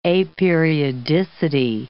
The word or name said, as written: aperiodicity